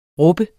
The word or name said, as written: rub